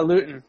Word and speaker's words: Luton: And how do you pronounce the last name? Luton? – Luton.